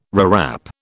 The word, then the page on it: rewrap: British English